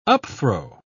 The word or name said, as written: upthrow